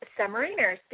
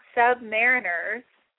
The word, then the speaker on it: submariners: I'm very proud of you that you said submariners, because it's not submariners. It's Submariners, and you did good. You said it right.